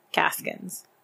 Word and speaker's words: Gaskins: Gaskins. – Spell it like it sounds.